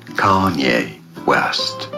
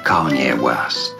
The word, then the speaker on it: Kanye West: Kanye West. Kanye West.